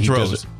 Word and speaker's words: Drozd: And Ben Droz. D-R-O-Z-D. The D is silent at the end.